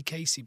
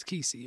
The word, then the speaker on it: Kesey: So I'm always tempted to say Casey, but it's not. It's Kesey, you're right. – I think it's Kesey. – No, it is Kesey.